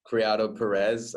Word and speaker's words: Criado-Perez: Yes, it's a Spanish surname, rather long. Criado-Perez, I guess, would be the English way of pronouncing it.